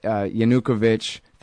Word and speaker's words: Yanukovych: this is basically giving up everything that Yanukovych, that's how you say it, has basically capitulated entirely.